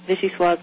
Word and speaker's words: vichyssoise: Because of the final E. – Right. – In that word. – Right, so it's vichyssoise.